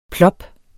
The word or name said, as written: plop